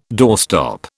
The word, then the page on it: doorstop: British English